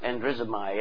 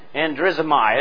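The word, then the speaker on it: androzomai (ἀνδρίζομαι): It's the word androzomahe, or androzomahe. I'm not exactly sure. You know how that's pronounced? Is it he or he? – No, it's androzomai.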